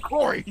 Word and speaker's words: Khori: And then, how did you spell Corey again? – K-H-O-R-I. Remember, this isn't England. Corey isn't spelled with C-O-R-Y.